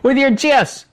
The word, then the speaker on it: GIF: The creator of the GIF, graphic interchange format, said that he pronounces it GIF, just like the peanut butter.